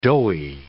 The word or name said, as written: doughy